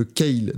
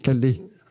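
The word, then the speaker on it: Kalle: Oui, pourquoi Parce qu'en fait, toi, tu le prononces comme la, nourriture verte, là, le truc, les gens. Le, le légume, le kale. Non, c'est Calais.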